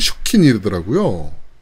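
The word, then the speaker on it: 集金: 수금의 일본 발음이 슈킹이더라고요.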